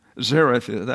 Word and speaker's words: Zarephath: Zarephath actually is the best way to pronounce it.